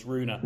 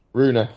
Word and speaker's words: Rune: Have to pull you up on the pronunciation. It's Runa. – Runa. Sorry. Right. Runa.